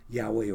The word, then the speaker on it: Yahweh: Well, the word Lord is usually in the Hebrew, Yahweh or Jehovah, some pronounce it, but Yahweh is more accurate.